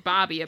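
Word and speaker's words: Bobbie: And the testimony was from not Bob Ye, even though it's spelled Bob Ye. It's pronounced Bobby, apparently.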